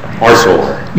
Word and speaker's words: Arcelor: What is the correct pronunciation of the first word, although we all refer to it as Arcelor? – Arcelor.